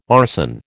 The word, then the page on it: arson: American English